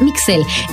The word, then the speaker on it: Migsel: Puedes encontrarme como Mixel Montoya. Oye, pero ¿cómo se escribe tu nombre? Se escucha muy raro. ¿Cómo se escribe Itzel, Mixel? ¿Cómo? Es Mixel. M-I-G-S-E-L. Como si escribieras Miguel.